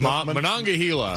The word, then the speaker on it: Monongahela: Yeah, so supposedly this river, the Monongahela, I don't know how to pronounce Pittsburgh stuff. Monongahela. – Close enough.